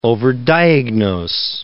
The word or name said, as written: overdiagnose